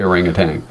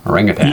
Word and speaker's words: orangutan: Although, when you look at orangutan, the way it's spelled, there's no G on the end, but we say orangutan, don't we? – Well, you do.